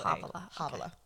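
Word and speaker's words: Havola: That like, does it start with that CH sound too? Like, no, it's actually, it's H-A-V-O-L-A. – Havala.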